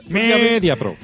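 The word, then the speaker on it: Mediapro: O Media Pro, no sé cómo se dice, pues claro, Fulgencio... – Media Pro. – Eso es.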